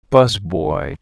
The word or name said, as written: busboy